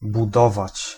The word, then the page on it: budować: [buˈdɔvat͡ɕ]; /bu.ˈdɔ.vaʨ/